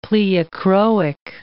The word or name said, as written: pleochroic